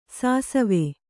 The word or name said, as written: ಸಿಸವೆ